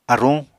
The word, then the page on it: aruñ: Apatani